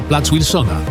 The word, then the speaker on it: plac Wilsona: Przypominamy, że poprawna wymowa to plac Wilsona z polskim W na początku.